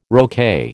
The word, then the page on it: roquet: American English